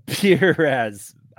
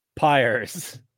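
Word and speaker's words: Pires: Perez, no, it's Pyers.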